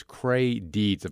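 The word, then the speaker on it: Creigh Deeds: The senator's name is Cray Deeds, if I'm saying that right, You are.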